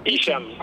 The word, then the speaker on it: Hicham: Ah, e si pronuncia? – Hicham, così liscio Hicham. Com'è, è un Hicham. Po', sì, lettura francese, diciamo. – Ah, alla francese.